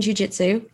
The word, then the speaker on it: jiu-jitsu: You were doing jiu-jitsu.